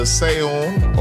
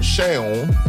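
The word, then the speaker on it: Seun: And you pronounce the name either Seon or Sheon. depending on the region.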